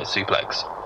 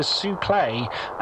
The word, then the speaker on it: suplex: Uh, yes, I've, I've, I, am getting quite frustrated with um, the announcer mispronouncing that word because he always calls it a souple, and much like you mispronounce it, because of course we know it's called a suplex.